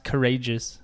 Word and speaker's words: Karagias: It's spelled like Karagias, but it's pronounced Courageous.